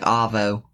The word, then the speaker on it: arvo: Oh, it's not R. It's probably spelled Arvo, but they don't pronounce the R, so it sounds like Avo.